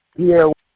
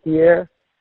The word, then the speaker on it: Pierre: Pierre. – D'Aire? – Yeah, Pierre. – Can you spell it for me? – P-I-E-R-R-E.